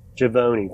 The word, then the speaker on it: Givony: Givony, thank you very much.